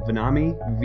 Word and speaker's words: Vonnahme: My last name is spelled Vonahme, V-O-N-N-A-H-M-E.